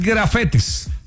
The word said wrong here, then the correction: gafete: Ah, ok. – Es gafete. – Válido. No lo entendía, no lo pronuncié bien, tiene toda la razón.